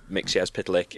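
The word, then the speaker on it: Mxyzptlk: Mixyezpytlek is that in John Byrne's Superman run, he has him spell it out phonetically so that you know how to say it.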